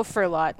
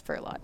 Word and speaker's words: Furlotte: Oh, Furlotte. – Furlotte? – Yeah. – Okay. – Yeah, that's how the Maritimers say it.